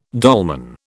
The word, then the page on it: dolman: British English